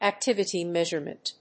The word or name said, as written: activity measurement